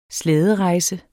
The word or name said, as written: slæderejse